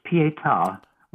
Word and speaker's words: Pietà: It's Pietà, with an accent on the final A.